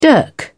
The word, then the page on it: dirk: British English